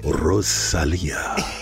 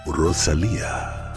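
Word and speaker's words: Rosalía: Rosalia. I understand they call you Rosalia.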